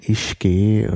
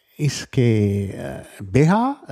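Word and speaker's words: uisge: Je nach Ort, Ischgä oder so ausgesprochen. Also ist immer die Frage, ist das schottisches Gälisch oder irisches Gälisch, also sind die Aussprache ist etwas unterschiedlich. Ischgä, Ischgä, Ischgä und Ischgä eigentlich auch mit palatalisiertem K und das ist, heißt Wasser, aber der Ausdruck ist eigentlich vollständig Ischgä Beha, Beha, also Wasser des Lebens, Beha ist das Leben.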